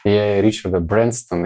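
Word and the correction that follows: Branson: или как-то не знаю. – Брэнсон.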